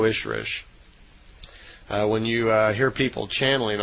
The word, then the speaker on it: Ishrish: And Semi in their language is E-L-O, so Elo. And a female Ishwish is called an Ishrish, I-S-H-R-I-S-H. So, in effect, Semyasi is an Elo Ishrish, is how they would pronounce it in her language.